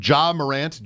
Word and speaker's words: Ja Morant: Ja Morant? Is that how you say it? Murray State? Ja Morant? J-A? – Yep.